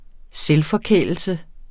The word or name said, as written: selvforkælelse